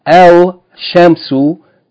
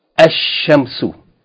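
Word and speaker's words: ash-shamsu (الشمس): You're not going to pronounce L, but rather you're going to double the sun letter, and therefore we see it written with a shadda. So, al shamsu is correctly pronounced ash-shamsu.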